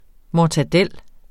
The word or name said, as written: mortadella